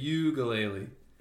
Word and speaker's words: Uiagalelei: DJ Uiagalelei. – Uiagalelei. – Like a ukulele.